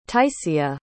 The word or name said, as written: taisiya